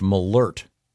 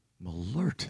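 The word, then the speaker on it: Malört: And this says it's pronounced malert. – Malert. – There's a, an umlaut, the two dots over the O? This has a pronouncer saying malert.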